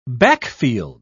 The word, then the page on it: backfield: British English